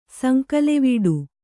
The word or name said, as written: ಸಂಕಲೆವಡೆ